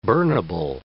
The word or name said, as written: burnable